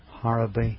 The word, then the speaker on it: Harrowby: Our street name is spelt H-A-R-R-O-W-B-Y, pronounced Harraby Street.